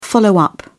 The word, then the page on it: follow-up: British English